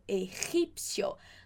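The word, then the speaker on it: egipcio: Então, yo doy clases de russo, tu das clases de ruso a um jogador de futebol egípcio. Só cuidado aqui com essa pronúncia de novo. O G, esse como se estivesse dando uma escarrada mesmo na garganta aqui.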